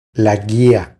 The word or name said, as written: La guia